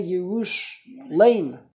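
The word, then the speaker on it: Yerushalayim (ירושלים): Why do we pronounce it as if it says a yud mem? Yerushalayim. Yud mem always means plural.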